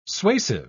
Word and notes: suasive: British English

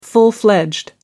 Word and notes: full fledged: American English

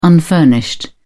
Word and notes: unfurnished: British English

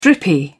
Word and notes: drippy: British English

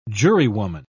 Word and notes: jurywoman: British English

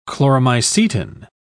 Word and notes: chloromycetin: British English

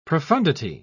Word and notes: profoundity: British English

